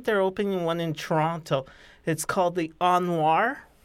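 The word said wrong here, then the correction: O.Noir: Did I pronounce that right? – Onwar. – It's Onwar.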